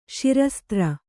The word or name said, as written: ಶಿರಸ್ತ್ರ